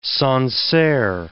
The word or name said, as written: Sancerre